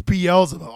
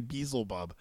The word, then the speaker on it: Beelzebub: It's Beelzebub. All those times I was saying Beelzebub, I was putting the wrong emphasis on the wrong syllable.